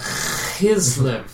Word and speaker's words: Chislev: Now it happened in the month of Chislev. I don't know how to pronounce that. Do you know how to pronounce that? – Chislev. – Chislev? Okay. – It's the back of the throat.